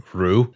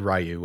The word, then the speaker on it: Ryu: So I was approximately today years old when I found out that the proper way to pronounce that is just Ru.